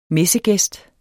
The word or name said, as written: messegæst